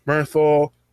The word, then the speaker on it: Bernthal: I felt like a whole series of John Bernt, I forgot how to say his name, Bernthal being Punisher, I'm for it, and I support it.